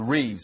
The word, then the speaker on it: riv: It's R-I-V. It's spelled, if you see it in literature, R-I-V, but the V, the Bet, is pronounced with a V sometimes, depending on how it's noted in the Hebrew. So it's pronounced Reeve. So you can spell it R-I-B or R-I-V.